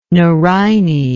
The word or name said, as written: nerine